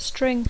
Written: string

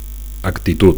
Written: actitud

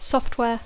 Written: software

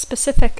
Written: specific

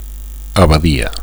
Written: abadia

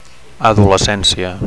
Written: adolescencia

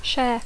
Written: share

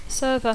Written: server

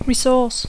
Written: resource

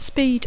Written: speed

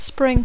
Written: spring

